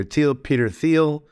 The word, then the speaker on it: Thiel: Peter Thiel is the name of the, people pronounce it Peter Thiel.